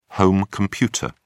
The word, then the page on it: home computer: British English